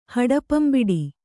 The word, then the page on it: ಹಡಪಂಬಿಡಿ: Kannada